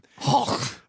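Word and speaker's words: Hauk: H-A-U-K. I don't know how to pronounce his name. – Hulk.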